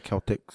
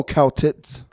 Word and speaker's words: Celtics: The C is a hard K sounding, not an S. – So it's pronounced the Celtics, Celtic. Yeah. But people call them the Celtics. – Okay, Celtics.